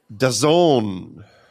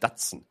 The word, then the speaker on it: DAZN: Das heißt, bis ich zum allerersten Mal jemanden hab Dazon hören sagen, habe ich echt gedacht, das heißt Datsen.